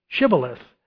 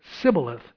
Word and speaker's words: Shibboleth: And if he said no, then they would say to him, "Say Shibboleth," and he would say Sibboleth, for he could not pronounce it right.